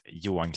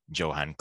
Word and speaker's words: Johan: In Swedish, it is Johan Kling, and in mm-hmm. English, it is uh, Johan Kling, I suppose. uh, our, our J is like your Y because our Y is always a vowel.